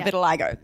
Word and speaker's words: vitiligo: Anyway, my mother said it was for my vitiligo. Is that how you say it? Yeah, vitiligo.